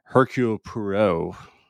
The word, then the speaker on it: Hercule Poirot: It's the third movie in the Kenneth Branagh, or sorry, correct me if I say this wrong, Hercule Poirot.